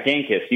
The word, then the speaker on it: Gankas: Now, did I pronounce his last name correctly? – Yeah, Genkis, you got it.